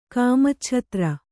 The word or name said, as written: ಕಾಮಚ್ಛತ್ರ